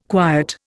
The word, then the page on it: quiet: Indian